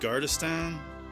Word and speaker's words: Gardestad: So, first one is Ted Gardestan? – Yeah. – Did I say it right? – You said it right.